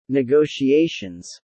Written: negotiations

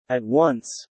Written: at once

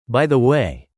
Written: By the way

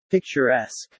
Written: picturesque

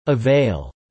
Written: avail

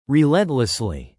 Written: relentlessly

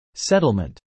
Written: settlement

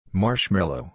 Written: marshmallow

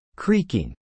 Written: creaking

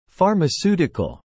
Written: pharmaceutical

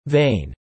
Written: vein

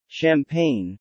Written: champagne

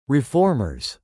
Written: reformers

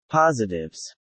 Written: positives

